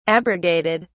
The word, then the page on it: abrogated: British English